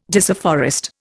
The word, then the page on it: disafforest: Indian